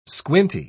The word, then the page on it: squinty: British English